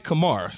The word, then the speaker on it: Kamara: If they're at full strength, right, given the fact that you have Michael Thomas, you have Emmanuel Sanders, Kamara, if I said it right. – Kamara, Alvin Kamara.